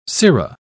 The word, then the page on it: cero: British English